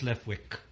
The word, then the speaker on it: Leftwich: How do you pronounce this? Leftwich? – I think it's Leftwich.